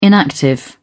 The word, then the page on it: inactive: British English